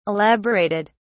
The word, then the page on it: elaborated: British English